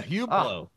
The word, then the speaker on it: Hublot: Yeah, Hublot. That's what they say. – Hublot, is that what it is? – Yeah, Hublot.